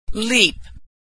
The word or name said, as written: leap